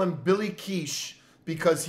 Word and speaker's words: kitsch: It is indeed Kitsch. Mm. Which sounds a lot, which makes more sense now.